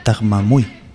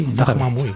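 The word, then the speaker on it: Taghmaoui: También Hubert Koundé y Saïd Tahmamoui. – Exactamente. – Así, no sé si se dice así. – Tahmamoui. – Tahmamoui. – Sí, sí, sí. Me gusta Tahmamoui.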